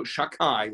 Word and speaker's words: Shakai: So Shakai, which I'm pronouncing that way because it's respectful, is one of the names of HaKadosh Baruch Hu.